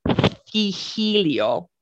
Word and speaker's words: Cixilo: C-I-X-I-L-O. – Wow. Okay. – Yeah. – Why not? – Why not? – Okay. – I think it's they would probably pronounce it Hihilio, right? With the TH.